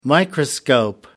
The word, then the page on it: microscope: American English